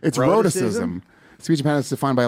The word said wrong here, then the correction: rhotacism: Rhotacism. They can't even say their own disease name.